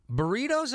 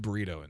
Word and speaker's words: burrito: Burrito's a nice. – How do you say burrito in Spanish? – Burrito. – Oh, is it? Do you have to roll the R? – Burrito.